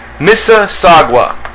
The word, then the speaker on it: Mississauga: Mississauga. Is that correct, guys? – Mississauga? – That's how you pronounce it? – Good. – Oh, yeah. Mississauga.